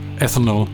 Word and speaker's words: ethanol: Except for one thing, for years I've been pronouncing the word ethanol wrong. I thought it was called ethanol. That's what you get for being Danish.